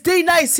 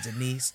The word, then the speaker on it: Denise: So he was mispronouncing all the names. And so it was like, D-Nice. D is D-Nice here. And of course it was Denise.